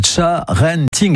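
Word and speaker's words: sharenting: vous nous parlez des risques du charenting. Ça se prononce donc charenting exactement, hein.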